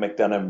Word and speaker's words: McDonough: In social media, Jim McDonough Music, MCDONOUGH.